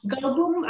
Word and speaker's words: Goldblum: Coplum. – Goldblum, ajá, nunca sé pronunciar su apellido, pero vamos a ese sector.